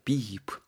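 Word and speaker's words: beep: 비입. 여러분, beef, BEEF랑 구분해야 됩니다.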